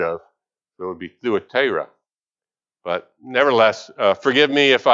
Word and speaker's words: Thyatira: Most people pronounce it Thyatira, I guess. But the U is actually hides the Greek letter there is the upsilon, and so it would have a U sound to it.